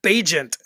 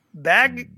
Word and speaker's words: Bagent: Tyson Bagent, apparently, is how you pronounce it. It took me about a half an hour to figure out how to pronounce his name. I was like, what? What? Because they didn't say it. I didn't hear him say his name when he came in. So all I had was like the back of his jersey. Yeah. And I was like, Tyson Bagnet? Bagnet?